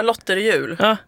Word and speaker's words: Lotterjul: Du har också drivit hashtaggen, nu vet jag inte hur jag ska uttala det, Lotterjul. – Ja, Lotterjul brukar jag säga.